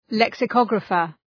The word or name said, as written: lexicographer